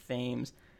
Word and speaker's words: Thames: Thames is pronounced. – Is it? – It's pronounced not like the river, but like Thames.